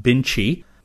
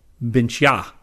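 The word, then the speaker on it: Bintje: Bart told me that the Dutch potatoes I was talking about in the August 2011 Science News are not pronounced Binchi, they are pronounced Binchia, A as in again.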